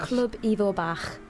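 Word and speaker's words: Clwb Ifor Bach: Club Ivo Bach.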